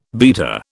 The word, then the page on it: beta: British English